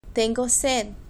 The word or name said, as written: tengo sed